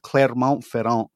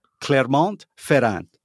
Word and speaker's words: Clermont-Ferrand: Clermont-Ferrand. – Okay, you don't pronounce the T, but anyway, that's pretty good.